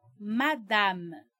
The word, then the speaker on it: madame: Uma outra palavra que muita gente conhece, mas acaba pronunciando errado quando está falando em francês, é madame. Ouve com atenção. Eu não disse madame. Esse A não é nasalado.